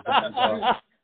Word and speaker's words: Van Gogh: If anyone's listening and they're like an art historian, they're going to be pissed if I don't say Van Gogh. So I'll say Van Gogh. That was the way his name was actually pronounced.